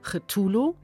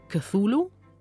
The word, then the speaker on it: Cthulhu: Also der Clou bei diesem Namen Cthulhu ist natürlich, dass man den eigentlich nicht aussprechen kann. Und darum ist Cthulhu, dass er das ungefähr so ausgesprochen hat, das wissen wir von Freunden von ihm.